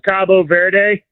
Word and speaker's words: Cabo Verde: In Spanish, it's Cabo Verde. – Cabo Verde. – Cabo San Lucas or whatever in Mexico. Cabo Verde. But okay. The English pronunciation is Cape Verde.